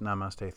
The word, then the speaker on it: Namaste: But you do say you introduced Namaste theory.